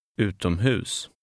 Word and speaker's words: utomhus: In number ten, the last syllable is stressed. Read number ten. – Utomhus.